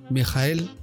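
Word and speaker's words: Michael: Y el muy weón igualó el récord de Mijael, así se pronuncia, Mijael Schumacher.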